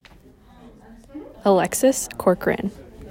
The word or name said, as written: Alexis Corcoran